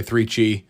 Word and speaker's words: 3Chi: That's right, 3Chi, number 3, Chi, it rhymes.